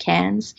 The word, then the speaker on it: Cairns: Again, it looks like Cairns, but they pronounce it Cairns.